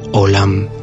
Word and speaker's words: olam: En primer lugar, mencionar que la palabra mundo en hebreo se pronuncia olam.